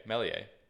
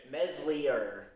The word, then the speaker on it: Meslier: Mellier. The S is silent. – We're not French. – Sure. – You know, so Mellier. – Mellier. – Meslier. – Meslier, if you're American.